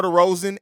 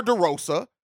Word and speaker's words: DeRozan: First of all, Ricky, you try to defend DeMar DeRozan, and you can't even pronounce his name correctly. It's not DeMar DeRosa. His name does not end with A.